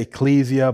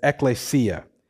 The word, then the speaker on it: ecclesia: I always have to look at it to make sure I'm saying it right, okay? I know people say ecclesia, but it's ecclesia, which means a gathering of citizens called out.